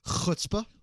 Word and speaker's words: chutzpah: And when someone has a lot of nerve, you say they have chutzpah. – Chutzpah. – Yeah. No, don't roll your R's. – Oh, sorry. – It's not a culture of R rolling.